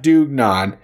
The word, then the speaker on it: dugnad: pronounced Dugnad, which is a form of volunteer communal labor.